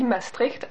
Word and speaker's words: Maastricht: Tel qu'on dit Maastricht à Maastricht, parce que dans, au nord des Pays-Bas, la prononciation est beaucoup plus gutturale.